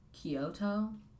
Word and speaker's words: Kyoto: She also has a song called Kyoto. I think that's how you pronounce it. Very good.